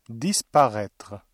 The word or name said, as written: disparaître